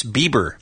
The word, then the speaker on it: Bieber: By the way, for folks who are already writing strongly worded letters complaining about the show, it's Bieber. Bieber like Justin Bieber. Not correct. What you think he said, okay? – Correct, yes. Bieber is spelled B-I-E-B, as in boy, E-R.